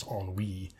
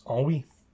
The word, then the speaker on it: ennui: So I learned that it was ennui. Mm-hmm. And I thought, okay, yeah, that rhymes much, much better than died of ennui. So, okay. Mm-hmm. It's ennui.